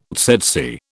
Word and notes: tsetse: British English

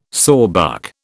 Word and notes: sawbuck: British English

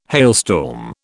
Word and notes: hailstorm: British English

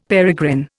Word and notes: peregrine: Indian

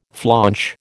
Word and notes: flaunch: American English

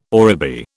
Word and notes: oribi: British English

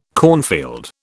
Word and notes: cornfield: British English

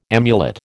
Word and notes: amulet: American English

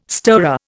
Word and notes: stirrer: Indian

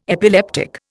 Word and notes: epileptic: Indian

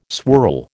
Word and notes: swirl: American English

